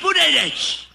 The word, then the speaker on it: Buttigieg: You say Boot? and then add edge, Buttigieg.